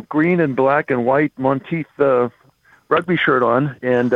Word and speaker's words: Monteith: Mo- is it is it Monteith? Yeah, Monteith.